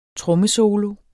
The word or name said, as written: trommesolo